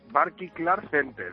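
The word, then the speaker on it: Barclaycard Center: Como logopeda, porque no sabes, es Barky Clark Center, el Palacio de los Deportes.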